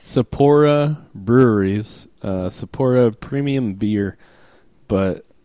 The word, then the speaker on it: Sapporo: Sapporo? – Sapporo.